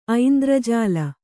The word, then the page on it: ಐಂದ್ರಜಾಲ: aindrajāla